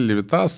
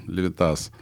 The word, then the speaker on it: Левитас: И мою фамилию произносили Левитас, созвучно там с Левитаном, Левитас.